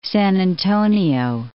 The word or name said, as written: San Antonio